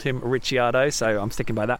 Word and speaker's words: Ricciardo: It's Daniel Ricciardo, because his surname is Italian, so forget the fact he was born in Australia.